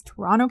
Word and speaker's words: Toronto: In Toronto, sorry, Lauren, I know you say you don't pronounce the T's, Toronto, Canada, which shows a beautiful valley with lush hills.